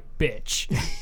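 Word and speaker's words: bič: It's a Czech noun meaning a whip, which happens to be pronounced just like bitch. So it's B-I-C, but it's pronounced bitch.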